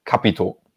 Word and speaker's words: Capito: Aber alle, die es jetzt gesehen haben, die wissen jetzt, Capito. Betonung liegt auf dem A. So schwätzt mal halt dem Siegerland.